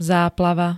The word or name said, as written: záplava